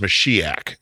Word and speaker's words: Mashiach: But chet is a hard H sound. So that's why we transliterate it as an H. But really, if you're going to pronounce it in Hebrew, it's Mashiach.